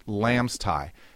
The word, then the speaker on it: LAMSTAIH: it's an acronym which is pronounced Lamb's Tie, and the acronym is LAMSTAIH.